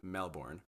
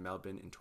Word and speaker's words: Melbourne: One, he is born, raised, and currently resides in Melbourne, Australia, or as some Americans say it, Melbourne.